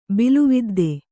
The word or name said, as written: ಬಿಲುವಿದ್ದೆ